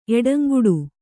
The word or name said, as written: ಎಡಂಗುಡು